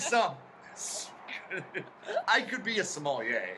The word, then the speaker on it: sommelier: Oh, by the way, Miranda's also the smellier. – A smellier. – Is that how you spell that? Am I saying that? – Sommelier? – Yeah, smellier.